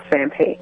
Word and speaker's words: Sampe: On Facebook, you can follow me on my music page or my personal page at April Sampe.